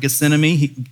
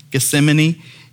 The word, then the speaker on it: Gethsemane: He was the first to fall asleep there in the Garden of Gethsemane. I always say that wrong. Gethsemane.